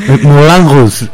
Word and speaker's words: Moulin Rouge: Sí, sí, te cuidado porque aquí con la pronunciación son muy estrictos, ¿eh? Que tenemos varias profesoras que enseguida nos... Moulin Rouge, como... Tienes que poner esa... – sí, esa nasal, esa nariz.